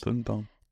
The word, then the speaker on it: Birnbaum: Spricht man den so aus? Ich weiß es nicht. – Birnbaum.